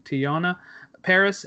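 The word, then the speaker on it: Teyonah Parris: and Tanya Paris, I don't know if I'm saying that right, Tiana Paris as adult Monica Rambeau, she was the little kid in Captain Marvel.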